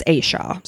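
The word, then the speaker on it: Asha: It's A-S-H-A is how it's spelled, but it's Aisha.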